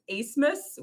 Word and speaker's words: Asmus: My favorite thing about Max is his last name is actually pronounced not at all like it looks. It's Asmus, which those are not the letters that are there.